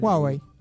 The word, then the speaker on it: Huawei: Huawei.